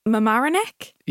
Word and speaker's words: Mamaroneck: Mamaroneck? – Yeah.